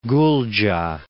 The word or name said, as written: Gulja